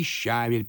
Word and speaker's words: щавель: Еще летом дачники щавель приносят. Так мы у них покупаем и в супермаркет относим». – «Извините, но вы опять неправильно говорите. В моем словаре указано, что ударение надо ставить на второй слог. Щавель».